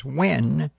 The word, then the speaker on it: Nguyen: I always knew her as France Nguyen, N-U-Y-E-N. They pronounce that now as Wen.